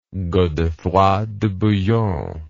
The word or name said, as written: godefroy de bouillon